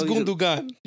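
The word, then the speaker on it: Gündoğan: It is Gundogan. You said Gundogan.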